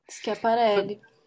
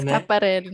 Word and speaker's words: Schiaparelli: Dessas peças da Schiaparelli, eu não sei como é que fala o nome dessa, dessa marca. – Schiaparelli. – É, Schiaparelli, né? Schiaparelli. Né?